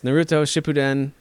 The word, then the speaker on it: Naruto Shippuden: Naruto, I'm going to butcher this, Naruto Shippuden Ultimate Ninja Storm 4.